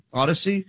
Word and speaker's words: Audacy: The Inquirer and Audacy, is that how you say it? – Odyssey.